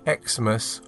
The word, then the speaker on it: Xmas: and they say that it can be pronounced Xmas or Christmas when you see it written down as XMAS.